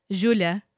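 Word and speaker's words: Júlia: Julia. There's an accent on the U.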